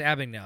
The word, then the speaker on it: Abagnale: I think it's Abagnale.